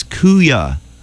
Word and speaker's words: Kuya: And also Thursday, there's the Silver Screams, Kuya, Electric Joey, Wirelines at AS220 Providence, Rhode Island. It's K-U-Y-A. How would you say that? – Kuya. – Kuya.